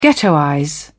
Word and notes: ghettoize: British English

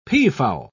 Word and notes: peafowl: British English

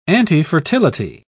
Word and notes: antifertility: British English

